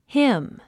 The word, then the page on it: hymn: hím; ヒム